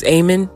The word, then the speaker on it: Amon: And, um, but there are some recipes in here and this might be controversial, but like because of David Ammon. Uh, I say his name so wrong. It's Amon, but um, because of what he and his wife talk about, like corn is the devil.